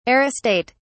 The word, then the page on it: aristate: British English